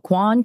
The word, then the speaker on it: Kwan: My last name is spelled Kwan, K-W-A-N.